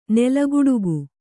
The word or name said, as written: ನೆಲಗುಡುಗು